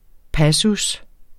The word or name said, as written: passus